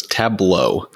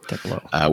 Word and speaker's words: Tableau: It's Tableau. Tableau.